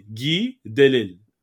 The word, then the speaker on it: Guy Delisle: Guy Delisle. You got it. – Perfect. It's French, so you just kind of drop all the S's and kind of like mumble your name.